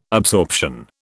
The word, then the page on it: absorption: British English